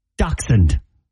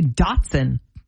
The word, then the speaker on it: dachshund: I'm going to say dachshund. – And I think that's exactly right. – Is there a k in it? I was going to say Dotson.